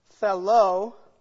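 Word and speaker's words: thelo (θέλω): The other term that we're looking at is either thelo. We'll put it also English, T-H-E-L, long O.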